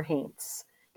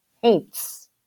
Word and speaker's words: haints: H-A-I-N-T-S? How is that pronounced? – Oh, haints. Haints. Well, that's the easy part, the haints. I mean, when I grew up in Eastern Kentucky, ghosts were called haints.